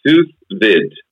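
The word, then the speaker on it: sous vide: So this one is Sousvid. – Sousvid. – Yeah. – Okay. – So Sousvid. V-I-D-E.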